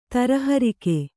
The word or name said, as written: ತರಹರಿಕೆ